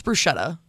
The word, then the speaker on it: bruschetta: Did you get a bruschetta? – Mm-hmm. – A bruschetta? – Yeah, we're not saying it like that. It's bruschetta.